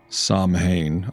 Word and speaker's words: Samhain: Samhain.